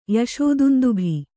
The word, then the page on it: ಯಶೋದುಂದುಭಿ: yaṣō dundubhi